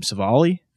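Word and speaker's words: Civale: Aaron, how do you pronounce Aaron's last name? Savali? – Mm-hmm.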